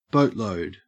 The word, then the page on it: boatload: Australian English